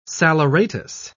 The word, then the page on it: saleratus: British English